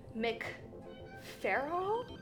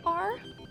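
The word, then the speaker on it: MacFarquhar: And it was written by Larissa, here's another horrible pronunciation, McFarrell. It's McFarrell.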